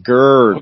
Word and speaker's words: gird: Gird, G-I-R-D. That is pronounced like G-U-R-D. Gird.